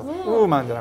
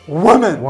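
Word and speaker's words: woman: ウーマンじゃなくてウォーマン。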